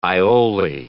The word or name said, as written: aioli